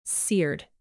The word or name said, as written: seared